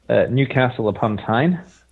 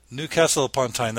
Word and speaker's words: Newcastle-upon-Tyne: Newcastle-upon-Tyne. – Newcastle-upon-Tyne.